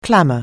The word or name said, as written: clamour